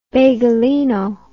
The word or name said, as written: bagelino